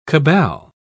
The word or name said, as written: cabal